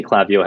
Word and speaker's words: Klaviyo: Have I said that right? – Yeah. So basically, Klaviyo has two sides to its agency partners.